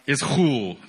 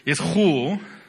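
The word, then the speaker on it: is hul (ἰσχύω): In the Greek, it's pronounced, is hul. I can't wait to learn Greek when I go to seminary, right? Is hul. You have to pronounce it like that, is hul.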